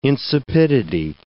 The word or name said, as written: insipidity